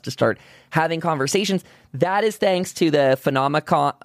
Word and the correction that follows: Phenomenicon: I just said it wrong, didn't I? Phenomenicon.